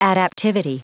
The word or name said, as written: adaptivity